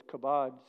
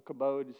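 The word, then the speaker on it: kabod (כבוד): But here's what's interesting, is the Hebrew word for glory is kabod, C-H-A-B-O-D, kabod, or kabod, some people pronounce it. And it literally meant weight, the weight of something, W-E-I-G-H-T, the weight of something, the pressure of something.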